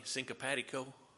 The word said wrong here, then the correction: copacetic: copacetic, uh, however it is.